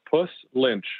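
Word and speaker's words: Puslinch: Well, as think about hanging cats in trees. So a puss lynch. So pussy cats lynched in trees.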